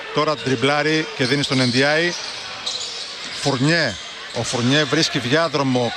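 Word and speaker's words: Ndiaye: Και λέω Ενδιάη γιατί στο site της Ευρωλίγκας, όπως είπε και ο Γιάννης, ο Σενεγαλέζος το προφέρει έτσι.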